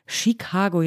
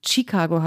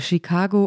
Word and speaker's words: Chicago: habe ich falsch ausgesprochen, nämlich mit einem viel zu starken T, also Chicago habe ich gesagt. Also ich habe es sehr deutsch ausgesprochen in diesem deutschen Podcast, aber natürlich äh, jeder, der die Stadt so liebt, muss sie auch richtig aussprechen.